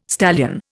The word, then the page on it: stallion: Indian